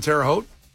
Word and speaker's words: Terre Haute: He attended Indiana State University, by the way, so very local guy, born in Terre Haute. Am I saying that right, Terre Haute, Case? – Yes, that's how you say it.